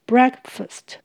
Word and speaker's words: breakfast: 不过请留意，早餐这个字要念成 breakfast。